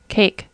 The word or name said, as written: cake